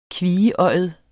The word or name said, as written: kvieøjet